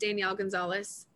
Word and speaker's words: Danielle Gonzales: It's just Danielle Gonzales, flat out.